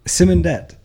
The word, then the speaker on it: Simondet: It's the female version of Simon in France. – I'm not that familiar with French. I know that it's a French word that we pronounce non-French. – How's the French pronunciation? – Would be Simondet. That's as much about my own history as I know. – Oh, so it's E.T., not E-T-T-E? – Just E.T.